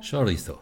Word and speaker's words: Chorizo: Chorizo, habe genau.